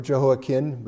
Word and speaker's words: Jehoiachin: king Jehoiachin, or Jehoiachin, but if you say the CH, you can tell him from the other one, it sounds just like the same thing if you pronounce it right.